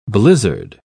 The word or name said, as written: blizzard